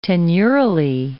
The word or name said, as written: tenurially